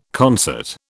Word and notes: concert: British English

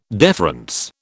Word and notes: deference: British English

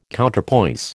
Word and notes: Counterpoise: American English

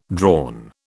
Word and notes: drawn: British English

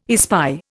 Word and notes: espy: Indian

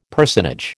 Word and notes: personage: American English

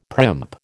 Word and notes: primp: American English

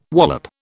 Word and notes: wallop: British English

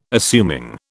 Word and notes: assuming: British English

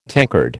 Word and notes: tankard: American English

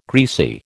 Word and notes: greasy: American English

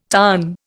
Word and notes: tarn: Indian

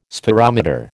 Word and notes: spherometer: American English

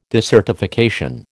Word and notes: desertification: American English

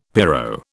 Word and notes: pyro: British English

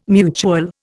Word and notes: mutual: Indian

